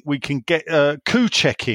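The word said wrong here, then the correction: Souček: in? Sorry, Soucek.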